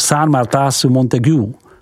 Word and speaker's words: Saint-Martin-sous-Montaigu: Mercurey, vyslov Mercure, a Saint-Martin-sous-Montaigu, vyslov Saint-Martin-sous-Montaigu.